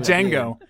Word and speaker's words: Django: You know, did Django unwind, and all kinds of. I pronounced awful. – The D, didn't I? I'm a terrible human. – Django. The D is silent.